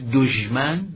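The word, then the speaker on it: دشمن: که ظاهرت دژم و صفتهایی که منفیست با دژ، مثل دژمن، دژ باید با حرف پیش به اصطلاح حرکت پیش تلفظ کرد. دژمن، دژم، همیشه در تلفظ این واژه ها دژمن رو به یاد بیاریم، تلفظ این واژه رو. کلن واژه های به طور کلی صفات منفی با حرکت پیش تلفظ میشن.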